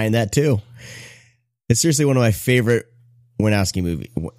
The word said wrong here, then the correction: Wynorski: How do you pronounce that? Warnowski. Listen to the new Splathouse episode, by the way. You'll hear Mike and Jim Wynorski going back and forth trying to say his name correctly. It's fantastic. A little plug there for Splathouse. It's great. Wynorski.